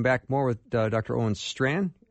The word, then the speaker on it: Strachan: And the way you spell his last name is S-T-R-A-C-H-A-N, although it's pronounced Strand.